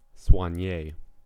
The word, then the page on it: soignee: American English